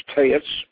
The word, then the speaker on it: TEX: He received and transcribed his visions of the 30 aethers, starting with the lowest, which is Taitz, and it's spelled Tex, by the way, but it's pronounced Taitz.